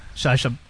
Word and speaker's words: Sacha: Sacha. Is that how you say his name? – Yeah, I didn't like his role.